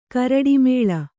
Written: ಕರಡಿಮೇಳ

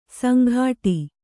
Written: ಸಂಗಟಿ

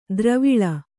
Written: ದ್ರಾವಿಳ

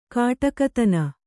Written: ಕೊಡುಗೈತನ

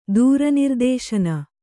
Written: ದೂರನಿರ್ದೇಶನ

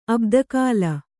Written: ಅಬ್ದಕಾಲ

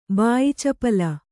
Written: ಬಾಯಿಚಪಲ

